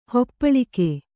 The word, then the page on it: ಹಪ್ಪಳಿಕೆ: Kannada